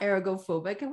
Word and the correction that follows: agoraphobia: What is it? – Agoraphobia.